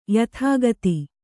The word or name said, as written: ಯಥಾಗತಿ